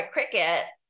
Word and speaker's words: Cricut: And also, if you told me you've got to try Cricut, I would spell it like the bug. – Cricket, because that's how you spell Cricut.